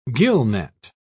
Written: gillnet

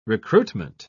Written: recruitment

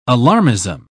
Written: alarmism

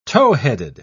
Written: towheaded

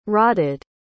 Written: rodded

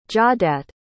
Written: jawdat

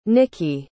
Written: nikkie

